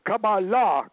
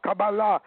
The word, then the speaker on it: Qabalah: He said, "You don't even call it, you don't even pronounce the word right. It's Qabalah, Qabalah."